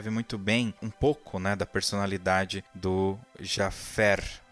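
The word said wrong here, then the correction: Javert: É isso? Falei certo? – Javé. Javé. Javé. – Javé. De vaca.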